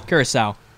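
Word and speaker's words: Curaçao: He's 22 years old from Curacao. Did I pronounce that right? – Curacao.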